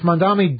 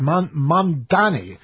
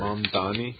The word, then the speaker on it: Mamdani: If Mamdani does lose, see, I said it wrong there, too. Sometimes I get it wrong. I said Mamdani. Mamdani. We can all practice together. – Mamdani.